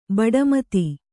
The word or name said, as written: ಭಾಟ್ಟಮತ